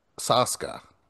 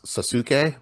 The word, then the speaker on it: Sasuke: I love Ninja Warrior Japan, or as it's called, Sasuke. When you look at it, you think it should be pronounced Sasuke, but it's not. It's Sasuke.